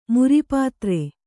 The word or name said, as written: ಮರುಪತ್ರ